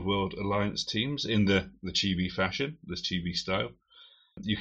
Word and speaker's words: Chibi: The first one that we're looking at is Chibi Ball. Or Chibi Ball? How do you pronounce that, Morgan? – Chibi. Chibi. Chibi. That's how you say it.